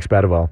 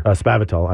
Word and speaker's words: Spavital: Texas State has parted ways with its head coach, Jake Spavital. – Bummer. – Spavital, I should say.